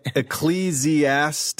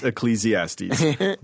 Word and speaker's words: Ecclesiastes: Zorba added, this is funny, Zorba added a sixth syllable, pronouncing it Ecclesiastes. – That's right. That's right. That's Ecclesiastes. – Wrong. The correct pronunciation is Ecclesiastes.